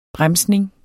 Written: bremsning

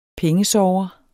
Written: pengesorger

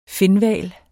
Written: finhval

